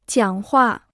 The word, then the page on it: 讲话: jiǎng huà